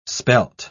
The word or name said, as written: spelt